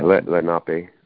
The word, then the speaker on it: Lenape: Manhattan has been returned to the Lenape. Am I saying that correctly? – Yeah, Lenape.